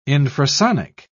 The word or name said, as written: infrasonic